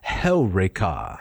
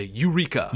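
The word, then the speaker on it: heureka: And we leave the Latin behind momentarily, and we come to Helreka. – Helreka. – Yeah. – Yes, exactly. Although most people would say Eureka. – Eureka. Right. They would put the emphasis on the penult. – On the wrong syllable.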